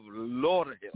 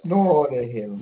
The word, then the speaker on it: Lauderhill: You didn't say it right. We are in the beautiful city of Lauderhill. – Lauderhill.